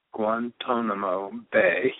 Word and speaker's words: Guantanamo Bay: We're going to talk about something that most people don't know zip about, and that is the Guantanamo Bay. Do we pronounce that correct? Guantanamo Bay.